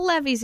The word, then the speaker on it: levee: Must be a lot of levees in the country. – Yeah, I know what you're saying. They do talk about levees. Levees, tractors. – It rhymes with Chevy.